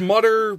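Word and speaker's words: Mütter: The Mummers Museum. – No, the Mutter Museum. – Mutter Museum. – Mutter Museum. – I'm really rolling today, mispronouncing stuff. – It's Mutter, but the guy added an umlaut over the U.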